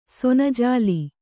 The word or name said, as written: ಸೊನಜಾಲಿ